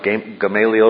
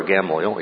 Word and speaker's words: Gamaliel: You'll hear it pronounced Gamaliel or Gamaliel, either one.